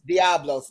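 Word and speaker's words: diabolos: The word devil in the Greek is how do you pronounce that, beloved? – Diablos. – Diablos, that's it.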